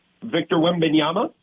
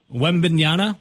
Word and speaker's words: Wembanyama: Victor Wembanyama. – Wembanyama.